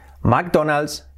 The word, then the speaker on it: McDonald's: McDonald's, pues sería McDonald's, McDonald's, sería así. McDonald's, McDonald's, Mac, no sería Mac, McDonald's, McDonald's. Con la L, la, la, la, la, letra clave, McDonald's. We're going to McDonald's, ¿vale?